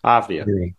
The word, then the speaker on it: Avdija: Avdija. – Avdija.